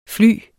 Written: flyer

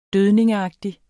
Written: dødning

